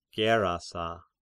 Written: Gerasa

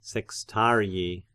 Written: sextarii

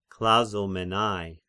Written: Klazomenai

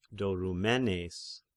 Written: Dorymenes

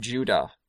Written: Judah

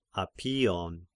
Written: Apion

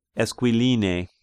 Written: Esquiline

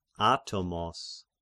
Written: Atomos

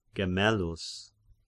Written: Gemellus

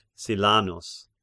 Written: Silanus

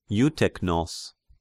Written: Euteknos

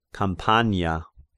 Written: Campania

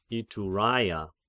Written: Ituraia

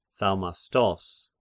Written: Thaumastos

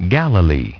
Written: Galilee

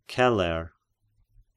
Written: Celer

